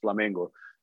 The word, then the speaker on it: Flamengo: That's how you say it in Portuguese, Flamengo. So it's actually like Flamengo.